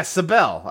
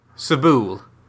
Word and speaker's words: Sable: Oh, yeah, Sabelle. I played a little bit of that. – Yeah, I pronounced it like rhymed with Mabel.